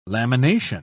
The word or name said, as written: lamination